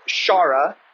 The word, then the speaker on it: Ciara: Her name is spelled C-I-A-R-A, but it's pronounced Shara.